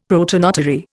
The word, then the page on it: protonotary: Indian